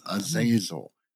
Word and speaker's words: Azazel: And I know a lot of people would say, oh, no, that's not how you say that name. It's Azazel. Get it out of your head. This is not the right pronunciation. The movie Fallen is the worst piece of crap ever developed because they could not get the name of the demon right.